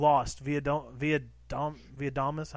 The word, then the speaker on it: Lost via Domus: And I've been playing Lost via Domus.